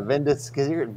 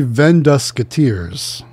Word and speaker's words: Vendusketeers: Vendiskiers? How do you say that? – Three Vendusketeers.